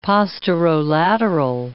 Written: posterolateral

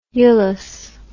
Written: ulous

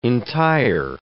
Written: entire

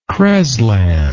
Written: creslan

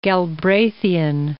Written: Galbraithian

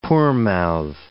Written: poor-mouth

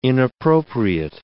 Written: inappropriate

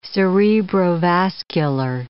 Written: cerebrovascular

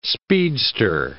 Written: speedster

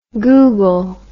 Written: google

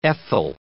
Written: ethyl

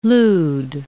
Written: lude

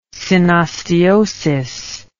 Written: synosteosis

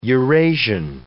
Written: Eurasian

